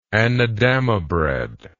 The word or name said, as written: anadama bread